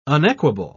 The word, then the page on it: unequable: British English